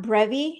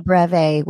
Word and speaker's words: breve: Linda, hot brevi. Is that how you say it? Brevi? – A breve with that's half breve.